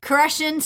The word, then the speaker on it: Koreshan: a place called Koreshan State Park. Am I yeah. even saying that right? Super unique. And I think you nailed it.